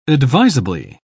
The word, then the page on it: advisably: British English